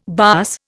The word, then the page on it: baas: Indian